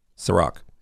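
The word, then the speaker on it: Cîroc: And it was this green apple Ciroc. Am I saying it right? – Yes. Ciroc. – Ciroc? – C-I-R-O-C. – Okay. I'll write that down. C-I-R-C.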